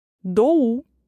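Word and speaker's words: doğa: Буква юмушак г не читается в словах, удлиняет предыдущую гласную, иногда придавая ей горловое звучание. Проще разобраться на примерах. Доу – природа.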